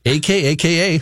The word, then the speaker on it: Akaka: help Senator Akaka? A K A K A. How would you say that? Akaka.